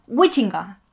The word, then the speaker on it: Huizinga: y para ello vamos a hablar de un libro que se llama Homo Ludens de Johan Wichinga. – Al parecer se pronuncia así.